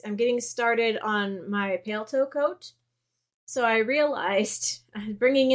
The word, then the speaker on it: paletot: Like, so if you look up a pale toe coat, which it's spelled P-A-L-E-T-O-T, it's French, it's French, so the last T is silent.